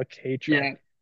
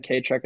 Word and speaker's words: kei: A K truck. – Yeah, if anyone's interested in what a K truck, it's spelled KEI.